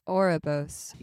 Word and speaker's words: Ouroboros: Ouroboros.